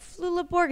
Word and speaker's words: Flula Borg: I love that right before the podcast, I was like, Flula Borg, is that how I say it? And you're like, that'll do. – Yeah. – Like, did I say it wrong? – No, it was perfect.